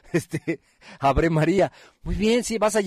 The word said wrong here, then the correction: Ave María: Ah, ¿no se dice así? No, no se dice así. Entonces, ¿cómo se dice? Se dice Ave María. Ave María, ¿ya lo dije bien? Sí.